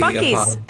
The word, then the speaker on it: Buc-ee's: Bussy, how do you pronounce B-U-C? – Buc-ee's. That's Buc-ee's.